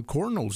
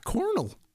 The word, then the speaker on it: kernel: Now that you mentioned it, I mean, I know how it's spelled, you know, with the K, kernel. I've just always called it a cornel.